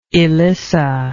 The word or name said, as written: elissa